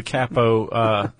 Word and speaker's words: capo: So that's how the capo... – Capo. – That's how the capo... is it capo, really? – Yeah.